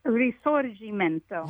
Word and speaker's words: Risorgimento: Risorgimento? – Risorgimento.